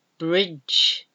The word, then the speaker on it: bridge: Bridge, bridge. You don't pronounce the E on the end of B R I D G E, bridge.